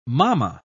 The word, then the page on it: mamma: British English